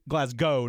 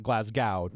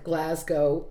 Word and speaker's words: Glasgow: Right. Glasgow, not Glasgow. – Right. – Right. – An emphasis on the first syllable.